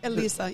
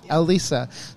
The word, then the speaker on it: Elisa: Elisa, yep. – So like, saying the letter L and then Lisa. Elisa.